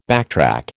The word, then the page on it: backtrack: American English